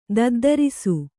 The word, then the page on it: ದದ್ದರಿಸು: daddarisu